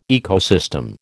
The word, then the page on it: ecosystem: American English